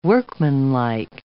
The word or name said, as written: workmanlike